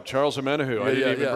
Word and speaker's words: Charles Omenihu: Charles Omenihu.